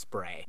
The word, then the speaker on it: Spree: I found out that the Spree River that runs through Berlin is perhaps actually pronounced spray.